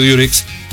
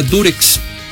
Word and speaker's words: Durex: el famoso Durex, que les digo, así se quedó en México precisamente por esta compañía que lo llevaba, la marca Durex. Así se llama Durex, no Durex. Lo que pasa es que nosotros pronunciamos, al decir Durex, pronunciamos la U como se pronuncia en inglés, U, ¿no?